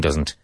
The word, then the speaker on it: doesn't: Pronounce the T and doesn't.